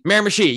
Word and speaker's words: Miramichi: And I am really excited to be in, how do you say, Miramichi? – Miramichi, yep.